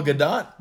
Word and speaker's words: Gadot: Gadot. – Oh, Gadot.